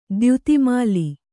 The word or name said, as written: ದ್ಯುತಿಮಾಲಿ